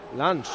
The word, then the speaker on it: Lens: Lens, efectivamente.